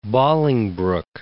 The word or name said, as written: Bolingbroke